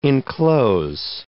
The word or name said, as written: inclose